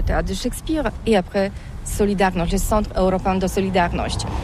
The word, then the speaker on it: Solidarność: Alors solidarité, en français on dit solidarnosc, mais ici à Gdansk on ne prononce pas solidarnosc. – On solidarność.